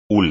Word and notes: hule: espagnol

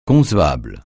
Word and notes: concessionnaire: francais

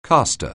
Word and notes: caster: anglais

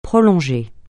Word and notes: prolonger: francais

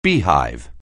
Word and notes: beehive: anglais

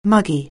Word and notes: muggy: anglais

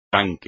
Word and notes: tanque: espagnol

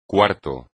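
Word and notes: cuarto: español

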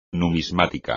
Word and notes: numismática: espagnol

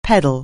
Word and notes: pedal: anglais

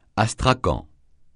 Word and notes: astrakan: francais

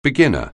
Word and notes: beginner: anglais

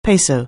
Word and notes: peso: anglais

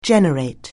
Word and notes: generate: anglais

